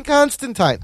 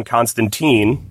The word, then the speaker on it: Constantine: The movie pronounced it John Constantine because we're Americans and that's how we read things. – John. Okay. I've been saying it wrong my whole life. John Constantine.